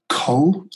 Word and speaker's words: Kohl: Yeah, so it's three films, one of them entitled Teachers, one of them entitled Felt Tip, and the third entitled Cole, which is spelt strangely, kind of phonetically, K-O-H-L, but it is a nod towards the word coal.